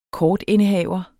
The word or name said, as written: kortindehaver